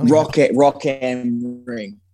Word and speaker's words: Rock am Ring: Is it Rock AM Ring or is it Rock AM? How do you say that? – Rock AM Ring.